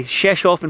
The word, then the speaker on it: Rzeszów: Ben Shalom, lovely Rzeszów in Poland, on a balcony of a hotel, talking boxing. What more can we ask for? – It's amazing. It is amazing. I would never come here. Good pronunciation.